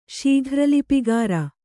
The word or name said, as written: ಶೀಘ್ರಲಿಪಿಗಾರ